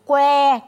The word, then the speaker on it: Quế: Q U E would be pronounced as Quay. Can you say quay? Quay. Quay means a stick.